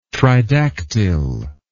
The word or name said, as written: tridactyl